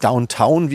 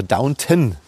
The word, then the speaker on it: Downton: Nicht Downtown, wie ich immer falsch gesagt habe. Ich habe auch bei YouTube Downtown gesagt, das war Downton.